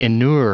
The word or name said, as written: inure